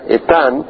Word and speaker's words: Etán: El nombre Etan en el hebreo, que es así como se, pronuncia Etan eh, quiere decir enduring en inglés, es decir, alguien que permanece, alguien que persevera en la adversidad.